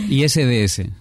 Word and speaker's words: ISDS: vamos a hablar del ISDE. ¿Lo he dicho bien? Buenas tardes. – ¿Qué tal? ISDS.